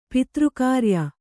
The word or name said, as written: ಪಿತೃಕಾರ್ಯ